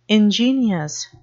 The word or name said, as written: ingenious